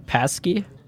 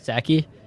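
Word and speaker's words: Psaki: How do you say her name? Paskey? – Saki. – Saki? – Yeah.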